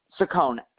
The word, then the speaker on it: Saccone: Saccone, S-A-C-C-O-N-E.